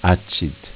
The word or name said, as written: Accid